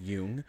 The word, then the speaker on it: Jung: I think it's called Jung, is how you pronounce it. – It's like a Jungian. Is it from Jungian theory? – I think so. It's spelled like J-U-N-G. Jung.